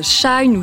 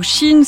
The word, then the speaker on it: Shein: Shine ou Shein, selon les prononciations, symbole de ce qu'on nomme l'ultra fast fashion, est aujourd'hui le premier vendeur de vêtements de France.